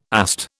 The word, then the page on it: AST: British English